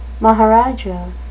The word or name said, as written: maharajah